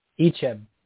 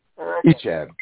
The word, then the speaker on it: Icheb: Icheb. – Icheb.